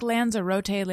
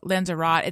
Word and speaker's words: Lanzarote: It's like Lanzarote, Lanzar- Lanzarote.